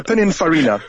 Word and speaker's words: Pininfarina: So this is an Italian car by a company whose name I can barely pronounce, Pininfarina. Pininfarina. There we go. – That's why I left it for you to say. – Pininfarina.